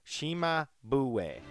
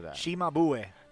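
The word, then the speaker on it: Cimabue: Shimabue. – I should know that. – Shimabue.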